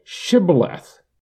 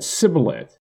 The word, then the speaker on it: Shibboleth: When he said no, they then said to him, "Then say Shibboleth." And he said Sibboleth, for he could not pronounce it right.